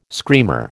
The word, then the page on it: screamer: American English